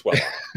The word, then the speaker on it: Wellock: First, coming back to Partner First, we have Mr. Reid Wellock, who has actually, Reid, did I just butcher your last name? Wellock or Wellock? – Everyone butchers it. I mean, you pick your poison, everyone butchers it, but it's Wellock.